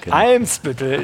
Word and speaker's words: Eimsbüttel: Ich sitze äh, und schaue Ali mit gut wimmelbildmäßig äh, auf den Weihnachtsmarkt von Hamburg Elmsbüttel. Äh, hoffe ich zumindest, dass ich das jetzt richtig gesagt habe. Elmsbüttel. Eimsbüttel. Genau. Ich werde schon korrigiert hier.